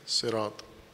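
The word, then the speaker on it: سراط: اصل عربی میں سین کے ساتھ ہیں سرات لیکن ایک لہجہ قریش کا لہجہ عربی کا وہ یہ تھا کہ وہ سین کے بجائے اس لفظ کو سواد کے ساتھ تلفظ کرتے تھے لہٰذا قرأت قرآن کریم میں اس کو سواد ہی لکھا گیا ہے لیکن اصل لفظ اس کا سین کے ساتھ ہے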